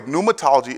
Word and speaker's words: pneumatology: By the way, pneumatology is spelled with a silent P, like pneumonia, P-N-E-U-M-A-tology, okay?